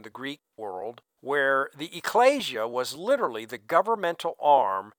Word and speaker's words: ecclesia: And so when Jesus said, "Upon this rock I will build my ecclesia," that's the correct pronunciation in Greek, he was saying, "I will build my governmental structure."